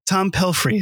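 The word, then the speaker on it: Tom Pelphrey: Tom Pelfrey. Is that how you pronounce it? That's oh, right. Oh, great.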